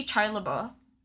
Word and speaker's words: Shia LaBeouf: So apparently, Shia LaBeouf. Is that how I say his name? – Yeah.